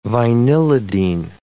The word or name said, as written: vinylidene